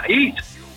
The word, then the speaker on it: Higgs: Mucha gente nos dice, oye, ¿cómo se pronuncia Higgs? Digo, no, Higgs.